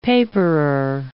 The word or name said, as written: paperer